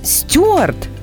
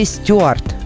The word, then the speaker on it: стюард: Вот человек, который работает на борту самолета, он стюард или стюард? – Ну, до недавнего времени там работали стюарды. Угу. А потому что в словарях только такой вариант был еще совсем недавно. – То есть с ударением на ю.